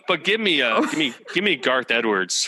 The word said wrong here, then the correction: Gareth Edwards: for the director. If I'm saying his name right. The guy did Rogue One. – Oh, Gareth. Yeah, Gareth Edwards.